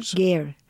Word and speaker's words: ger: The nomads live in something what they're called gurs? – Ger. It's a ger. It's pronounced ger. It's like a Russian yurt, but in Mongolian it's called ger.